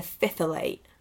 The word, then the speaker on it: phthalate: I've now found out how to pronounce it. It's a phthalate.